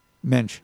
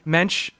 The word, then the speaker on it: Moench: I don't know how to say his last name. M-O-E-N-C-H? – Mensch. – Mensch.